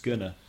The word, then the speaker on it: gonna: Just one thing that when you say going to, it's usually pronounced gonna.